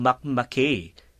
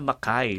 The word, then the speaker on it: Mackay: Pero nung una kong nabasa yan, uh, Martin, at mga kababayan, nakala ko Mac Mackay. Kasi mm-hmm. parang ganun mo siya ipapronounce yes. pag Actually, kasi dahil American ano tayo, di ba? Yeah. – So, ganun mo unang ma- yung pala, Makai, di ba?